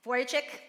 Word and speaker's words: Vujicic: I have no idea how to say his last name. Vujicic, there you go.